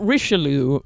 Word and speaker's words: Richelieu: Cardinal Richelieu, is that how you say it? – Oh, yes, that's right.